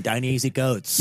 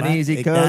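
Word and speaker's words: Ta-Nehisi Coates: Ibram Kendi and Don Easy Coats. – Yeah. – That's how you pronounce the fucking name. – Don Easy Coats.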